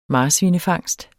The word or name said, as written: marsvinefangst